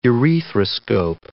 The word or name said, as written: urethroscope